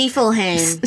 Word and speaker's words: Niflheim: Niflheim? Is that how they pronounce it? Niflheim.